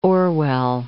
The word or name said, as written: Orwell